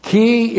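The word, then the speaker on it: key (כִּי): K long I key. It is a causal conjunction. It is correctly translated because key introduces the cause or the reason for all of the happiness in the millennial kingdom.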